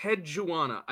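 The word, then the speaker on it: Enheduanna: Enhedwana. I think I have it now. – Yeah! Buddy, that was so good! – Thank you. I didn't realize it was like three syllables, so earlier it was like Enhedwana.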